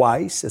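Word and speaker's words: Houaiss: o AIS, é assim que pronuncia, 228 mil.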